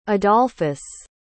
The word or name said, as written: adolphus